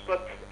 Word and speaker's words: Piotr: Ah, ça se prononce pote, comme touche pas à mon pote, pote D'accord.